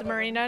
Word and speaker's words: Marina: My name is Marina Navarro, M-A-R-I-N-A.